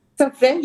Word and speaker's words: Sofra: Sofra? – Sofra, yeah.